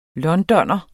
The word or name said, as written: londoner